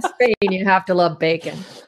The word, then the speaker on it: BACN: It's called Bacon, B-A-C-N, huh. pronounced as in bacon, mm-hmm.